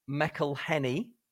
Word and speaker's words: McElhenney: I'm going to go from McElhenney. Is that how you pronounce it? Yeah, good. And Ryan Reynolds, obviously.